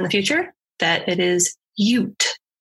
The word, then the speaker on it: Ute: So in the episode, I pronounced it as Ute, and it's actually Ute. – Oh. – Like Utah.